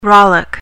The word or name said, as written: rollick